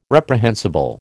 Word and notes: reprehensible: American English

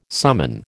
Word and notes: summon: American English

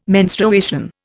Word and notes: menstruation: Indian

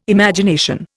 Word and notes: Imagination: Indian

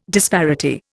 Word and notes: disparity: Indian

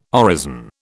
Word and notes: orison: British English

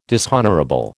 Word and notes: dishonourable: American English

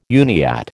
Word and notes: Uniat: American English

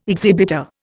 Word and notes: exhibitor: Indian

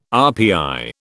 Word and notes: RPI: British English

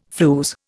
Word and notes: flews: Indian

